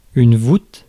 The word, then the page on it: roof: /ɹuːf/; /ɹʊf/; [ɹʊf]; [ɹuːf]; /rʊf/